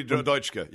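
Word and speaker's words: Dutschke: A German communist in the 60s named Rudi Deutschke. I'm not sure how to pronounce his last name. – Rudi Deutschke, yeah.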